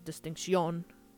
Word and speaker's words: distinción: For the most part, what that means is that they practice the linguistic terms of ceseo, spelled C-E-C-E-O, and distinción, spelled D-I-S-T-I-N-C-I-O-N. The O has an accent on it, which makes it distinción. The emphasis is on the O at the end.